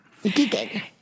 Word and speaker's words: Iquique: Iquiqueña.